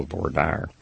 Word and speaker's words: voir dire: This is what we call voir dire. At least in Texas, that's how we pronounce it, and I'm sure in France they'd do it differently. But what happens when you get the juror on the stand, what they do, they explain the special issues.